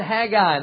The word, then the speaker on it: Haggai: All right, everybody find Haggai. That's how you pronounce it. My wife said, are you sure you say that Haggai? I thought it was Haggai. No, it's Haggai. Haggai is how you say it.